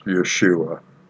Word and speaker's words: Yeshua: The Hebrew for Joshua is the letters Yud, Shin, Vav, and Ayin, pronounced Yeshua.